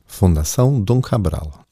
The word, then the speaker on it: Fundação Dom Cabral: Kannst du es aussprechen, wie man es richtig? – Fundação Dom Cabral.